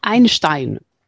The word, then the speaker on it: Einstein: Ah, o sea, no viene de Einstein. – Es de una pieza. No viene de Albert. – No viene de Einstein, como se diga en alemán. No sé, cómo se pronuncia. – Einstein.